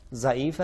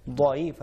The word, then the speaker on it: ضعیف: ضعیف ضعیف نہیں ہے, ضعیف ہے.